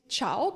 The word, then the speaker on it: Ciao: Und da haben wir jetzt gleich diese Kombination von C und I. Und die spricht man dann wie Chi aus. Also mit Ciao kann man sich das ganz leicht merken, weil folgt auf dem Buchstaben C jetzt ein E oder ein I, so wird dieses C dann wie Ch ausgesprochen.